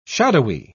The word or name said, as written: shadowy